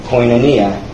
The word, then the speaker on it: koinonia: as koinonia. That's the pronunciation.